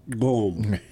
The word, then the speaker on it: Gqom: And I just included that just so that I could say Gom, which I don't know if I'm pronouncing that right, but I'm doing my best. G-Q-O-M is how it's spelled. – You get the click in there, you probably got it.